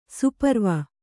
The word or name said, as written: ಸುಪರ್ವ